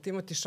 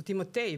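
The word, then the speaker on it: Timothée: Timothy Chalamet, odnosno Timotej bi on rekao da Timotej bi trebao.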